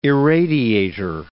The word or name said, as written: irradiator